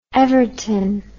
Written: everton

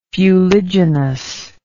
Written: fuliginousness

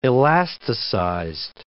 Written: elasticized

